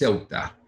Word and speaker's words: Ceuta: Help me again with the pronunciation. It is Ceuta. – Spelled Ceuta. Ceuta. – Right.